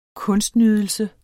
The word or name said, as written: kunstnydelse